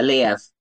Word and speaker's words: Liev: I would say it's Liev. Or Liev. Yeah, Liev. Yeah, like Kiev, his but Liev.